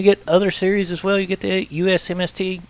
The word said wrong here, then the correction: USMTS: Well, I butchered that, didn't I? – USMTS. – USMTS. I got those letters completely twisted up.